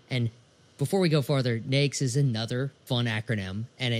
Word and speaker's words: NAICS: Well, basically, and it's NAICS. – Okay. – Kind of like N-A-K-E-S, NAICS.